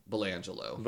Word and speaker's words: Belanglo: Well, Belangelo is also called, is spelled B-E-L-A-N-G-L-O. Belangelo. – Belangelo.